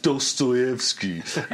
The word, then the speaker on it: Dostoevsky: Yes, it's pronounced Dostoevsky.